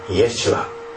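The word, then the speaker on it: イェシュア: ヨシュアという名前が、ね、今日の箇所に出てきます、ね、ヘブライ語で正しく発音するならばイエシュア、これはイエス・キリストのイエス様と全く同じ発音です。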